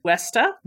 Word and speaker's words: Huesta: Amanda Brock, she's got, okay, and she told me how to pronounce it. It's Huesta. – Huesta, okay.